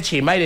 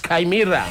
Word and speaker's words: Chimaira: Y no se dice Chimaira, es Caimirra. Sí, es en serio. Pues, ¡ey, me va a comprar el CD de Chimaira! Pero para que sepan, es Caimirra, listo, así se lee, se escribe Chimaira, pero se lee Caimirra, listo.